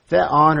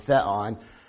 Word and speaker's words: Theon: Theon or Theos is the word for God. The reason it has a looks like a V, that's a new, pronounced with an N sound, Theon, instead of Theos.